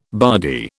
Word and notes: bardy: British English

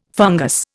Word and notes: fungus: Indian

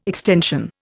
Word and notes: extension: Indian

